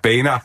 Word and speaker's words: Boehner: Det skal udtales Boehner, og det er jo noget andet, der er enormt morsomt, at Anthony Weiner bringer det ind her.